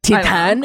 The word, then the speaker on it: Titane: Titane.